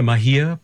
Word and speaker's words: Mahia: am I pronouncing it correctly, the Mahia Peninsula? – That's correct, yeah.